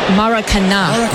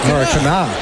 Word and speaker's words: Maracanã: Maracanã. – Maracanã.